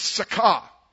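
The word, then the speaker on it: sakah (שכח): The word there forgot is a Hebrew word, is sakah. S-A-K-A-H, but if you're from where I'm from, you're a sucker, okay, if you forget God. So that's the pronunciation of the Hebrew word sakah.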